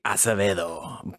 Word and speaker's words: Acevedo: It's supposed to be Acevedo, but uh, Acevedo. Acevedo is usually the, the the go-to.